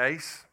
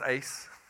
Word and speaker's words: Uys: I am JJ Ace. My surname is spelled U-Y-S. Don't say use. It's pronounced Ace, but you all know all about what funny pronounced surnames, right?